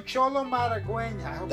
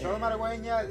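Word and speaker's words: Xolo Maridueña: Yes, my boy Cholo Maragüen. – That's how you say it. – Cholo Maragüen, this dude's awesome.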